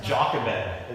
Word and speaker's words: Jochebed: Yeah, Jochebed is how. And so, I mean, there's not even a J sound in Hebrew.